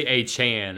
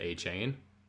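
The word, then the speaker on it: Achane: With all the speed, like that's kind of a boost for, you know, I would have to say A. Chan and Mostert. Apparently Devon came out and said that he wants his name pronounced A. Chan instead of A. Chain.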